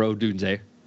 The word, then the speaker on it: Odunze: The difference, of course, Washington has Michael Penix to pair along with Odunze and or Odunze, I think that's how you say it, Polk and McMillan.